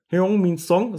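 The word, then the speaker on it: Heung-min Son: Hyung Min Song, das ist wunderbar ausgesprochen.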